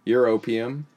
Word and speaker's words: europium: 620 years worth of europium, is how it's pronounced. These are weird words, sorry people.